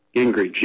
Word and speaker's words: Gingrich: Gingrich, yeah.